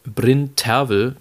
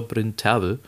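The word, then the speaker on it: Bryn Terfel: Ähm, mit äh, Jonas Kaufmann in der Hauptrolle von Peter Grimes, mit äh, Bryn Tervel, wie wir gehört haben, wie er richtig ausgesprochen wird, mit Sir Bryn Tervel.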